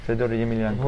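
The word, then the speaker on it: Fedor Emelianenko: D'ailleurs, est-ce que tu peux redire avec la super bonne prononciation parce que c'est vrai que les gens en France ne le prononcent pas bien. Vas-y. – Fedor Emilianko. – Voilà, exactement.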